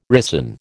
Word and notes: ricin: American English

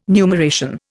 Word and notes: numeration: Indian

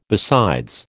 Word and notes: besides: American English

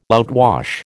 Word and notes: outwash: American English

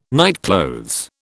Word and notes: nightclothes: British English

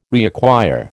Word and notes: reacquire: American English

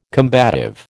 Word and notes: combative: American English

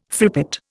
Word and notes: frippet: Indian

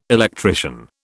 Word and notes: electrician: British English

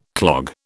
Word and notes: clog: British English

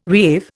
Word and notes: reave: Indian

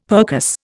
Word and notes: percuss: Indian

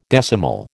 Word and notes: decimal: American English

